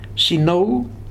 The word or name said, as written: Sinau